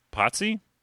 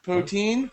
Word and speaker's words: poutine: That poutine, is that how you pronounce it? Poutine? – Potsy? – Poutine? – Poutine.